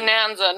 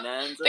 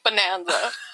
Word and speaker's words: Bonanza: Bonanza, not Bonanza? Think of banana, like Bonanza. Not Bonanza.